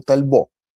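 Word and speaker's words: Talbot: И вы, кстати, тоже, может быть, помните, что 41-й москвич, он раньше был Тальбо. То, что пишется как Тальбот.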